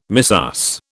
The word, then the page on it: missus: British English